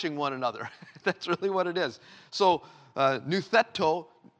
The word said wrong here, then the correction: noutheteo: Noutheteo, there we go, I'm sorry, I knew I didn't mispronounce it.